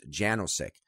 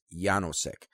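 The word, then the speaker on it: Jánošík: Now, the way his name is spelled, and the way I always heard it pronounced growing up, was Janosik, because it is a last name, and I remember I went to school with a guy whose last name was spelled the same way, but it was always pronounced Janosik. But from what I understand, the correct pronunciation is Janosik, and I'll tell why in just a moment.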